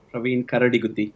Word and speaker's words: Praveen Karadiguddi: Praveen Karadiguddi.